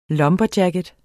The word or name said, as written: LUMBERJACKETS